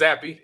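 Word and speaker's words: Zappe: I'm not going to mispronounce his name. – Zappy. It's Zappy.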